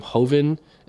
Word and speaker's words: Joven: So my last name, Hoven, is spelled Joven, and so I get Joven a lot, and you, Takeshi, get Takeshi, all that stuff.